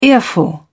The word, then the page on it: earful: British English